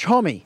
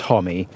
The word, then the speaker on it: Homme: But this episode features a rambly conversation with Josh Homme, frontman of rock music gang Queens of the Stone Age. And yes, it's pronounced Hommy, not Hom, like Bronhom.